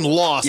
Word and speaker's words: LXST: You pronounce his name Lost, but it's spelled L-X-S-T.